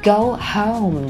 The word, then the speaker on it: home: 在说 Go home 的时候，注意尽量不要把 Go home 念成了 Go home。而应该是 go home, go home。